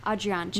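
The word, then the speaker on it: Adriaantje: And her nickname, or I guess probably the more French pronunciation of her name, was Adrianche. Mm-hmm. I guess it's not French. It's probably more Dutch. Adrianche. – Yeah. – So that's Adrianche.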